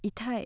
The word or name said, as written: itai